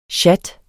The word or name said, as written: sjat